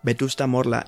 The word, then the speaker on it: Vetusta Morla: Vetusta Morla